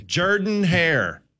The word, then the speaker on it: Jordan-Hare: It's Jordan-Hare Stadium. How many times do we need to tell people on ESPN? – If you're from across the pond, you still have to pronounce it correctly. – Jordan-Hare. That's how you say the stadium. It's not Jordan-Hare.